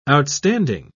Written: outstanding

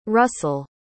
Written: russel

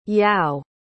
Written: yao

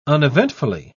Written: uneventfully

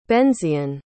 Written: Benzion

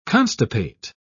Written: constipate

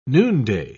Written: noonday

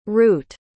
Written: rute

